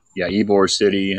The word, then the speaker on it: Ybor City: Was it Ybor City? Is that how you pronounce that? – Yeah. Ybor City.